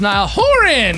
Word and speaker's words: Niall Horan: But I'm also glad he told me that because I think I've been pronouncing it wrong my entire life. – I was like, it's Niall Horan.